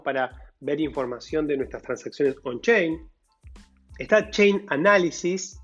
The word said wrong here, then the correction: Chainalysis: Chainalysis en realidad, chain Chainalysis, no me sale muy bien la pronunciación.